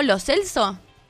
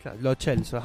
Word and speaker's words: Lo Celso: dijo Lo Celso. – O sea, que se pronuncia así. – Ajá. – Lo hemos dicho mal, haciéndonos los conchetos toda la vida. – Lo Celso, ajá.